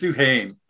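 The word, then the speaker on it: Duhaime: That fourth line, Duhamin, or I don't even know how to pronounce it. I'm phonetically trying to spell it. – And it's just I think it's just Duhame.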